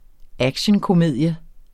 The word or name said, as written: actionkomedie